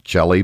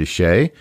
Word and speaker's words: Chelly: It's spelled Chelly. Kind of, you would think it might be pronounced Chelly, but it's not. It's Canyon de Chelly.